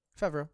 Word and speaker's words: Favreau: Favreau, is that how you pronounce his name? – Favreau, yeah.